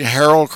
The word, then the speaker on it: Harrell: And am I pronouncing Harrell correctly? – It depends. Some people say Harold. If you're from Israel, you say Harel because it is a Jewish word.